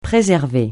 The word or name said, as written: préserver